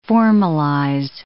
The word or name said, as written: formalize